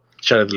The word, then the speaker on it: Jared: Y hablando del payaso, me acordé de, me dirán ustedes cómo se, pronuncia, exactamente, que Jared o Jared Leto. Jared Leto.